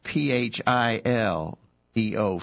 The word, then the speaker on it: phileo: Uh, there's another word for love in the in the Bible in, in the New Testament is the word phileo. And it's if if you were going to spell that with English letters, you'd spell it P H I L E O, phileo.